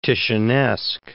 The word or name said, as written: Titianesque